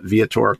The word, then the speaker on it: Viator: He is a worship leader, but also the owner of Viator. Is that, am I saying that correctly? Viator? – We pronounce it Viator Coffee.